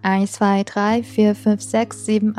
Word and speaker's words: sechs: 在这里边呢，需要大家注意的就是这个数字六的发音，嗯，词尾的 c、 h、 s 合在一起发 x 的音，所以是 sechs, sechs。